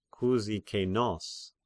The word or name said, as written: Kyzikenos